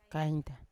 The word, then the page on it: kainta: Yawa